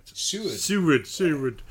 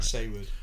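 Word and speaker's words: Saward: Seward, whose name I can never say right. – Seward. – I can never say his name right. – Seward.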